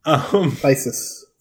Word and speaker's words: Phyresis: The word that I can't pronounce. – Phyresis. – Phyresis, yes.